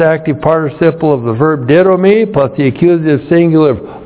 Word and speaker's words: didomi: And then we have the verb didomi. D-I-D long O-M-I. Didomi. Didomi means to give.